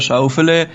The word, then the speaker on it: Schauffele: Denn seine Familie stammt aus Schwaben und sein Name würde dort wahrscheinlich deutlich anders ausgesprochen werden, weiß unsere Schwaben-Expertin Desiree Wolf. – Naja, dann wäre der Alexander Schauffele.